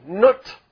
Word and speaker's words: Nut: Now, they have a sky goddess by the name Nut. Nut. N-U-T. You pronounce a nut. Nut.